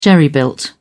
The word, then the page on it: jerry built: British English